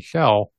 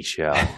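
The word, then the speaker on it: Shell: You mispronounced. It's Donnie Schell.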